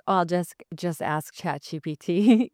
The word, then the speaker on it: ChatGPT: Okay, I was saying GBT for the longest time. I still say ChatGBT, but I know it's ChatGPT, which is why I asked, but yeah.